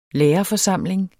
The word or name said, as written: lærerforsamling